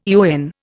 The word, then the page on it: eon: Indian